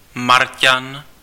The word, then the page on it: Marťan: /ˈmar.can/